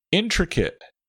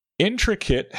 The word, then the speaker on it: intricate: Intricate. Intricate has a short I on all three syllables, even the one with the letter A. Intricate.